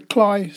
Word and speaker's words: Cley: We stopped at Cley next to Sea, that's spelt C-L-E-Y, but it's pronounced by the Norfolk Dumplings as Cley, so I call it Cley next to Sea, which is the scene of recent US helicopter crash on the salt marshes.